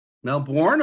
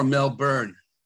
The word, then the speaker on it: Melbourne: Melbourne or Melbourne? – Melburnians pronounce it Melbourne. – Melbourne. Okay. – Yeah. Okay for North Americans to say Melbourne. We're used to it.